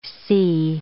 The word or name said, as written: cee